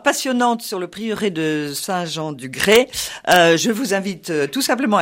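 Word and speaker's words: Saint-Jean-du-Grais: Écoutez, le plus simple, c'est que nous avons un site internet dont l'adresse est Saint-Jean-du-Gré, donc Saint-Jean-du-Gré, Gré, cela s'écrit G-R-A-I-S, tout attaché sans espace, sans rien, .com.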